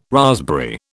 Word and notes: raspberry: British English

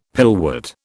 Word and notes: pillwort: British English

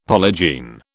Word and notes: polygene: British English

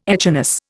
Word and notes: echinus: Indian

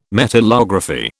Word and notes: metallography: British English

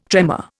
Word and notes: tremor: Indian

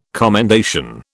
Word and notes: commendation: British English

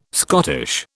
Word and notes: Scottish: British English

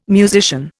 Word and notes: musician: Indian

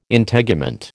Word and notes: Integument: American English

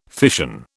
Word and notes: fission: British English

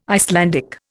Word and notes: Icelandic: Indian

